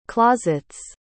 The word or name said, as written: closets